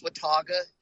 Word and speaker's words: Watauga: It's Watauga is kind of how I've been taught to say it, but I think your pronunciation is fine.